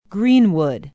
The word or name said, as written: greenwood